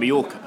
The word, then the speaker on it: Mallorca: As in, well, actually, the L's you'd pronounce as something else, wouldn't you? Well, because it's, if you think, double L is yeah, so as in Mallorca.